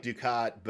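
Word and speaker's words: Dukhat: You pronounce it like Ducat, but you maybe try and slip the H in there a little.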